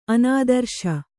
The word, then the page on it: ಅನಾದರ್ಶ: Kannada